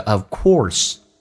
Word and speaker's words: of course: 来看一下，我们很喜欢把读成 of course，这是有问题，叫 of course， or 嘴巴要收圆，重度卷舌 of course。